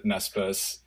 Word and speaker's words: Naspers: And I'm going to mispronounce it, but Naspers to you got the pronunciation. Naspers.